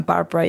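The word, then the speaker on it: Barbora: So the only place the listeners can find me is on Instagram, on Barbara Yoga.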